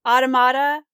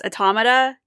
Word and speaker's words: automata: I used to say it automata. But a lot of people say it's automata, like automation, automata.